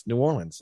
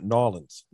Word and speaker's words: New Orleans: But the funny thing is, you pronounce New Orleans, New Orleans, and not Nawlins.